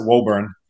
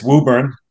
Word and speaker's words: Woburn: Anywhere else in the country, you'd pronounce it Woburn, but it's Woburn.